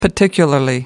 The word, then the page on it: particularly: American English